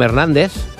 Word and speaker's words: Hernández: And the Z from Hernández sounds like thunder.